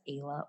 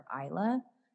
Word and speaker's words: Isla: So Isla can, like some people can either pronounce it as Ayla or Isla.